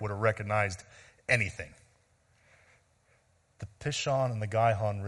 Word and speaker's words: Pishon: But where is this Pishon River? Did I say that right? Yeah, Pishon and the Gihon rivers.